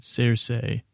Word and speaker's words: Cersei: One, Cersei. That's how Benioff and Weiss say it, Cersei. So I assume that's the correct pronunciation. And I think actually George R. R. Martin says it that way too.